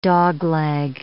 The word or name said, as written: dogleg